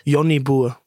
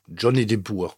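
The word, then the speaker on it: Jonnie Boer: Den spricht man nicht so aus. Sag mal, wie man es ausspricht. Johnny Burr. Johnny Burr. Sehr gut. Oder wie ich immer gesagt habe, Johnny de Boer.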